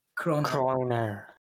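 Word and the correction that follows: Cronauer: Let's try to remember Kronauer.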